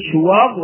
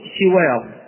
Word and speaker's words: شواظ: كلمة شواظ تنطق بضم الشين وكسرها لغتان وقرئهما في القراءات المتواترة، يعني تقول شواظ وتقول شواظ، فشواظ بالضم أو شواظ بالكسر بمعنى اللهب الذي لا دخان معه، اللهب الذي لا دخان معه.